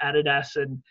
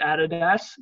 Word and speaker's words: Adidas: I had a video about Adidas and we pronounce it Adidas. The Americans pronounce it Adidas. – Yeah, I know. – And, yeah. – Yeah, but actually, if you go on their website, it's Adidas, so suck it, America.